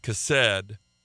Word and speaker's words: chesed: There's been lots of messages that preached on that, but I preached on the Hebrew word, and it's spelled C-H-E-C-E-D in the transliteration. It's pronounced chesed.